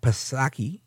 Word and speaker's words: Psaki: But the fact that you have the White House, Jen Psaki, silent P, of course,